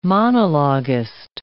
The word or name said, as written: monologuist